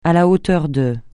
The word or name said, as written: à la hauteur de